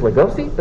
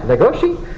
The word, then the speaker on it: Legoshi: So for me, I'm always going to pronounce it as Legosi, but then when I watch the anime and they pronounce it right, Legoshi, I get so confused.